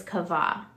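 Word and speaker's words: kavah (קוה): To wait in Hebrew is kavah. I don't know if I'm saying that correctly. Q-A-V-A-H. – Sounds good.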